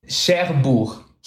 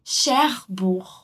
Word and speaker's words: Cherbourg: Cherbourg. – Cherbourg.